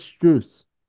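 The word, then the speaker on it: Strus: Max Struss is more clutch than Curry. – Hear me out. – Well, first of all, his last, you pronounced his last name right the first time. It's it's Struess.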